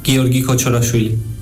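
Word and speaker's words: Giorgi Kochorashvili: Mi nombre es Giorgi Kochorafili.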